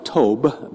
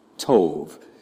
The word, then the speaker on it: tov: Looks like tob, but it's pronounced tov. And it's spelled T-O-V in modern Hebrew.